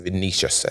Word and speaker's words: Vinicius: I'm not sure how to say his name. Vinicius, I believe it's correct pronunciation, with an assist from Lucas Moura.